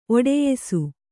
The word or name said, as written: ಒಟ್ಟಯಿಸು